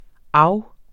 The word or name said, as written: af